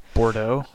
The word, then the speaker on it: Bordeaux: Bordeaux. Is that how you say that? – Bordeaux.